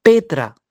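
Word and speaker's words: Petra (πέτρα): La palabra esta roca es la número 4073 de la concordancia Strong griega, que se pronuncia Petra, que significa roca gigante o masa de roca o roca principal o piedra principal.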